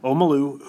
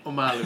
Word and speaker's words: Omalu: Matt's actually got an interview with Dr. Bennett Omalu, who popularized CTE. Did I butcher it? – Yeah. Omalu.